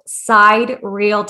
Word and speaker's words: sidereal: But that was until he found what is called side real time. It's spelled SIDEREAL.